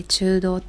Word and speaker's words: 中道: 中に道って書いて中道って読むんですけどこれ、